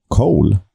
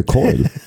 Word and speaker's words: Coyle: Eh, sen gjorde Louis Coyle, Coyle, Cole han heter inte Cole, han heter Coil.